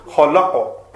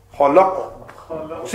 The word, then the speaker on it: khalaqa: So the correct way of pronouncing it would be... – is khalaqa. Khalaqa. See it? Khalaqa. Just a little bit of jerking sound.